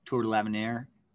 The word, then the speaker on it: Tour de l'Avenir: And then, you know, I listened to your podcast the other day of the 2010 Tour de l'Avenir.